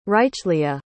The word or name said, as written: hrychleah